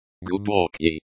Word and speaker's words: Глубокий: Глубокий. Глубокий, the G sound is similar to the G in go, but slightly softer. The O sound is similar to the O in hot.